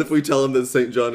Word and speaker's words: St. John: First name is spelled St. John. – But it is pronounced St. John? – It is pronounced St. John. – And they make a point of telling us that in the book? – No, but everyone that you will take a class from will make a point to let you know it's pronounced St. John.